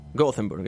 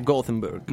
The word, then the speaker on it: Gothenburg: Gothenburg, hai visto come l'ho detto bene? Gothenburg.